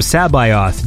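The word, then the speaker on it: C'baoth: Oh, I listened to the audiobook, and so it's because it's pronounced like they call him Sabaoth, Joris Sabaoth or something like that. – Right. – And then when I went to look him up on the Wikipedia, I couldn't find him because I didn't realize it was spelled like C apostrophe.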